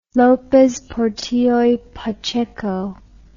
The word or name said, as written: Lopez Portillo y Pacheco